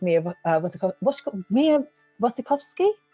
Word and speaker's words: Mia Wasikowska: And as I say, it's very sort of like reminiscent of Pieces with Mia Wasikowska.